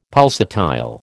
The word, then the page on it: pulsatile: American English